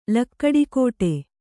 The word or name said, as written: ಲಕ್ಕಡಿಕೋಟೆ